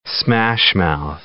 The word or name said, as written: smashmouth